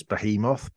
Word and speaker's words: behemoth: It's just incorrectly pronounced. It's behemoth, but I mean, if he wants to say it, how he wants to say it, he's the heavyweight champion of the world.